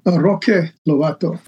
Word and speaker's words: Roque Lobato: Is that how you pronounce it? – Roque Lobato, yes.